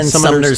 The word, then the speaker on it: Summoners: and The Ten Sumner's Tales. Did I even say that right? The Ten Sumner's Tales. The Ten Summoners' Tales.